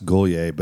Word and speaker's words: Guglia: It looks like Guli, so people think Uli Guli. So I have multiple VHS copies of The Wedding Singer, you know, from Julia Guglia. – Yeah. – But it's actually pronounced Goulier, but no one ever gets it right.